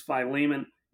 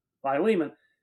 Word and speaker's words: Philemon: well, I think the way I heard it pronounced is Philemon,